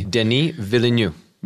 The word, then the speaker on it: Denis Villeneuve: Um, and this is it, Dennis Villeneuve. Uh, D- D- Dylan, Denis is you oh, pronounce it Denis. Denis. – Denis Villeneuve. Villeneuve.